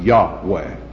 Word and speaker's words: Yahweh: Now, the other term is the one I want to spend 5 or 10 minutes on, and it's the term Yahweh. Now, that is spelled Y-A-H-W-E-H, and I don't want to move my outline. Yahweh. Y-A-H-W-E-H.